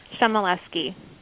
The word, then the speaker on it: Chmielewski: so for the cheapsies in the back, how do you say your last name? – Chamaleski.